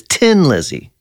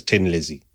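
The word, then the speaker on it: Thin Lizzy: I was always told, my brother, my older brother, Jim, told me that it was Tin Lizzy was how it's pronounced. – For real? – As a kid. – Kind of. I mean, if you said it with a very thick Dublin accent, it would come out as Tin Lizzy.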